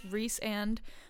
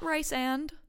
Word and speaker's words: Rhysand: Rhysand, like Reese's Pieces and Rhysand, not Rice and.